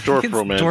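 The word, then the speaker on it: Dorfromantik: Is that how you say it? Like, didn't we call it dwarf romantic? – Is that what it was? – Yeah, it's dwarf romantic.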